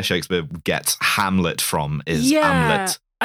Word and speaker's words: Amleth: This is Amleth, the pronunciation TH. This is Hamlet.